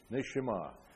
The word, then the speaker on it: neshama: I'll spell it for you. Neshama. N-E-S-H-E-M-A-H. N-E-S-H-A-M-A-H. Neshama. It's a unique word in Hebrew that pertains only to man.